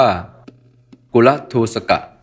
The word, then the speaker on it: กุลทูสก: สวัสดีครับวันนี้ก็พบกับรายการคำวัดวันนี้เสนอคำว่ากุลทูสกะ